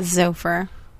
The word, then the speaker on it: Xover: Xover spelled X-O-V-E-R. I think that's Xover, right? – Sounds right. – Do you pronounce it with like the X turns into a Z? – Yeah, Xover.